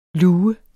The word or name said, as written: lue